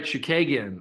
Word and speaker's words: Chookagian: My first pick is Katlyn Chookagian. That's right, Chookagian.